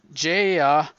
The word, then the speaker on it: Gaia: Jaya, who's Nature's Vengeance, kind of like Gia, but it's spelled G-A-I-A.